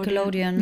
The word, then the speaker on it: Nickelodeon: Aber so ausgesprochen oder Nickelodeon? Nee, Nickelodeon. Nickelodeon, ne? Ja.